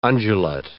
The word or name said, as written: undulate